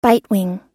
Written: bitewing

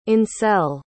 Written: Ancel

